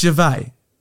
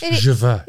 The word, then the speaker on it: Gervais: You're right. It's Gervais. He's French. – Gervais. – It is Gervais. – He's Italian. – With the S on the end. – Gervais.